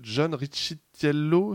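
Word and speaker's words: Riccitiello: on, a, Unity qui, a aussi perdu son patron en la personne de John Ricciello. Absolument. C'est ça, hein, c'est comme ça que ça se prononce.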